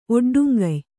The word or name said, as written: ಒಡ್ಡುಂಗಯ್